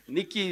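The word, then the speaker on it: Nicky: Nikki is not N-I-K-K-I, it's N-I-C-K-Y, and then type in my last name, and you'll find me somewhere on social media.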